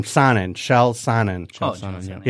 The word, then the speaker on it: Chael Sonnen: Shel Sonnen. – Yeah, he'll be a color. Okay, so I'm looking up his record. – Chael Sonnen, another guy that, you know, you've seen a lot of fighters doing that.